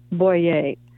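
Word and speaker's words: Bouyer: And so it's originally written by Father Louis, is it Beret? Louis? – In France he was French, so it would be pronounced Father Louis Boyer. Kind of think of St. Therese's father, Louis.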